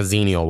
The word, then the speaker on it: Xenial: So there's Generation X and there's millennials, but there's like a 5-year gap where y'all are born at that they call Xenials. And I've seen this term a lot, and I didn't know what the fuck a Xenial was, but it's spelled with an X. So X-E-N-I, whatever, Xenial.